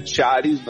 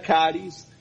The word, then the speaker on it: CHARIS: Algunos lo pronuncian Charis, lo pronunciamos nosotros, ¿cierto?, en Latinoamérica, pero digamos que el término sería Caris, que son una, por las iniciales en inglés.